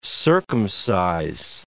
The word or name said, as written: circumcise